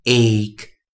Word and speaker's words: ache: 在前一堂课，老师教了，我们在教了一个很特别的尾音啊，c h e 发音为 k k k，a c h e 我们的发音为 ache ache ache。